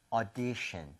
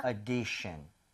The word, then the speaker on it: audition: 오디션이 아니라 5에서 조금만 더 벌려주시면 돼요. 그래서 audition, 아하. – audition. 조금만 벌려서 5보다는 차라리 어에 더 가까운 게 낫습니다.